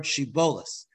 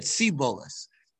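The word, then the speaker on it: Shibboleth: And they told them to pronounce the word Shibolis. And they said Sibolis that were apparently the people of, of Ephraim uh, uh, pronounced the shins as samachs.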